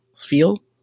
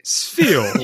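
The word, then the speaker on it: Spheal: Sfeel? Did I say it right? – Sfeel.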